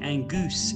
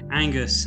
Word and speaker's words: Angus: That came from a project I did out in Rome, and the Italian film crew had trouble pronouncing my name. They called me Angus instead of Angus, and then it got truncated by the American producer to Goose.